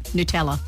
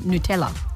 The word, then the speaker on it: Nutella: Yeah, that's how they say it, Nutella. – It just doesn't sound right. – I was doing the correct Italian pronunciation, Nutella.